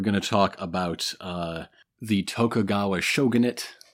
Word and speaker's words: Tokugawa: The Tokugawa.